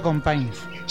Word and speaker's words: Companys: Se pronuncia Companys.